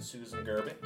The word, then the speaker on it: Susan Gerbic: We have with us Mark Edward and Susan Gerbic.